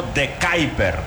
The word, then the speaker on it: De Kuyper: Entonces sabe cómo se pronuncia. Y ha dicho algo así como de Kuyper.